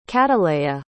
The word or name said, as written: cataleya